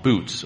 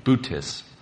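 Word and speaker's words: Boötes: and it's called Bootes, or it's Bootes, if you want to go with the correct pronunciation.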